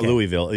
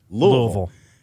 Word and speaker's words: Louisville: It's not Louisville either. It's Louisville.